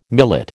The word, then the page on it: millet: American English